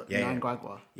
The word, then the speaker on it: Mnangagwa: It's not pronounced with the M. It's pronounced with the N. – And so Mnangagwa.